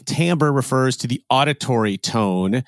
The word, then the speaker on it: timbre: do you know what the word timbre refers to in a musical context? – Wait, isn't that the word that is often mispronounced as timber, like timber? – Yes, it is. Timber is spelled T-I-M-B-E-R, whereas timbre is spelled T-I-M-B-R-E.